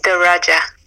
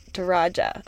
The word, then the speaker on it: Daraja: Um, it's pronounced Daraja. – Daraja.